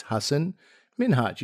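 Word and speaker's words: Hasan Minhaj: And he has this famous story where he was on Ellen and he walked Ellen back and said, listen, this is how you pronounce my name. It's Hassan Minhaj, you know, say it with me.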